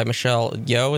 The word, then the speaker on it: Michelle Yeoh: And the mom character, played by Michelle Yeoh, is that how you pronounce that? – Yeah, okay.